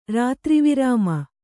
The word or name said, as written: ರಾತ್ರಿವಿರಾಮ